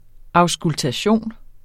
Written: auskultation

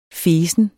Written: fesen

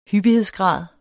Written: hyppighedsgrad

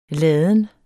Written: ladens

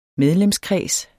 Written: medlemskreds

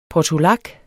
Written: portulak

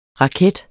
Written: raket

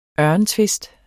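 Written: ØRENTVISTER

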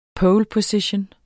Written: poleposition

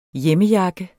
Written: hjemmejakke

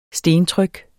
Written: STENTRYKKENES

